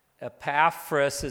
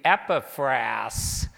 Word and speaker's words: Epaphras: Although Paul never visited the church in Colossae, he maintained contact with his ministry associate, and the pronunciation of this gentleman, Epaphras, is how I always knew it, but I looked it up, and actually the pronunciation should be Epaphras. It's a kind of a different way to pronounce it, but that's how in the pronunciation should be termed.